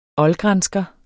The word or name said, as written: oldgransker